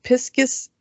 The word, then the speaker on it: Pisces: According to the Oxford Dictionary, apparently Piscus is a acceptable secondary pronunciation for Pisces.